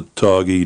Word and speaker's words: Targhee: Well, your flock consists of about 80 Targhee sheep. Did I pronounce that right? Targhee? – Yes. – It's T-A-R-G-H-E-E for anyone interested.